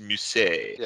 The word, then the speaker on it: Mousy: spelled Mousy but pronounced Mousset.